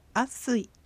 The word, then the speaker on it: アラスイ: 一回、一度という意味のアラスイ。 – アラスイ。 – このアラスイは続けて発音され、アッスイという発音になります。